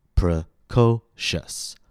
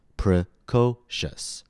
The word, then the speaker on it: precocious: So in order to maintain the alternating rhythm, we're going to make P-R-E, yes, you guessed it, unstressed, precocious, unstressed, stressed, unstressed, alternating rhythm. The end result is that the word is pronounced precocious, precocious. Feel the rhythm? Precocious.